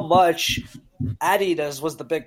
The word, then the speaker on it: Adidas: The other thing is Adidas, which is actually pronounced Adidas, was a massive European company, way bigger than Nike was at the time.